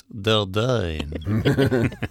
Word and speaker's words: Dordogne: It's actually pronounced Dordogne.